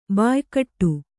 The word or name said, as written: ಬಾಯ್ಗೂಡು